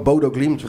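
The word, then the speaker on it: Bodø/Glimt: Hoe spreek je nou uit? – Gewoon Bodo Glimt. Wij spreken Glimt. Het gewoon op zijn Nederlands of op zijn Rotterdams uit.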